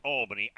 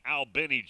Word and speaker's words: Albany: the Albany, not pronounced Albany, Albany, Georgia, Cam Davis, running back.